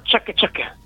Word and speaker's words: ciak ciak: K-k. – No, si pronuncia ciak ciak.